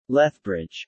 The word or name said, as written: Lethbridge